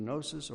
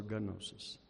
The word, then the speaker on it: gnosis: The Greek word tells us that kind of knowledge, and I probably mispronounce it because I think sometimes people pronounce it two ways. It's either gnosis or gnosis. Whatever you want. It's still the same word.